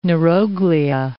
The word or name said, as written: neuroglia